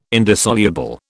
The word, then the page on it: indissoluble: British English